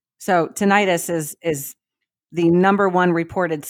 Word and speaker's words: tinnitus: So, yeah, the highest percentage of serious side effects from the COVID vax is, I don't know how to say it, tinnitus? – Tinnitus.